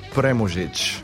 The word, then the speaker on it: Premužić: So I decided to call up an expert on these sorts of things, Tomas Chamorro Pramuzik, whose name I still really can't pronounce. Pramuzik? – In reality, it's Premužić.